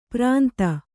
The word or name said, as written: ಪುರಂತ